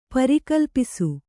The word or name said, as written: ಪರಿಕಲ್ಪಿಸು